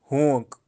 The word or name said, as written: hoank